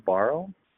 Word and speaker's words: Burrow: And I think he said recently, Joe, I might mispronounce his last name, Burrow, sounds about right.